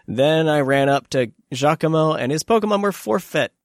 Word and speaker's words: Giacomo: I never know how to pronounce that. – Giacomo. It's Giacomo.